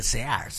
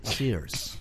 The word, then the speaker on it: Sears: No se dice Sears. – Se dice Sears Rothschild. – Se dice Sears.